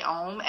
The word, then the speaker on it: Om: And it's that vibrational piece that's in the back of your throat when you say Om. And Om is actually sort of three syllables. It's that ah, that oh, and that piece at the end.